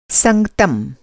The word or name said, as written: संगतम